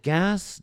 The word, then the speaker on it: GAAS: We pronounce it GAS, G-A-A-S, Globalization as a Service.